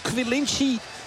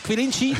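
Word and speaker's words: Quilindschy: En hij is vervangen door een nog piepjonge Quilinci. Zeg ik dat goed, Arno? – Ja. – Quilinci, Hartman.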